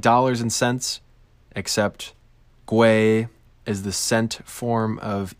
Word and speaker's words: gwei: That's spelled G-W-E-I, pronounced GWEI, which itself is a denomination of ETH.